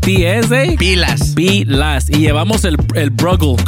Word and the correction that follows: Brugal: Al Brugal.